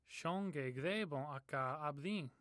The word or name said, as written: gthebon